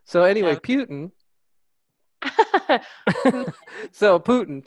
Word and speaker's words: Putin: I believe it is pronounced Putin. I think Putin would be as in Rasputin, who passed away.